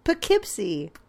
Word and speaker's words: Poughkeepsie: Poughkeepsie.